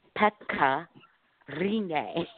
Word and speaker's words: Pekka Rinne: And in her video, she says that we say it wrong. The proper Finnish way to say it is Pekka Rinne.